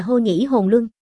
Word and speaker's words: Hồ Lộ: Hồ Lộ phủ này, chữ Hồ Lộ, vốn nên đọc là Hô Nhĩ Hồn Luân. Đây là phiên âm, có nghĩa là người Hồ, một đường vừa là phiên âm vừa là dịch nghĩa, coi như là tinh nhã đạt.